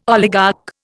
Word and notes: oligarch: Indian

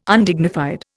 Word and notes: Undignified: Indian

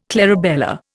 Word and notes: clarabella: Indian

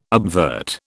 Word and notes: obvert: British English

